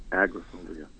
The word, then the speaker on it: agoraphobia: Thomas Jefferson, I have trouble saying the pronunciation of it, but it's like a ragophobia or he can't walk into a room with more than three or four people. – Oh, agoraphobia.